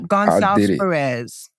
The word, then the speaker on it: Gonsalves-Perez: Gonsalves-Perez.